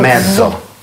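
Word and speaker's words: mezzo: Половина – это «медзо». – «Медзо». – То есть сколько-то часов «э»? – Можете еще раз произнести, простите, эту «медзо».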